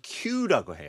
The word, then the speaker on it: queue: 근데 줄라는 단어는 Q-U-E-U-E. 발음은 Q. – Q. – 그냥 Q. – 그죠? – 네네네. 그래서 Q라고 해요.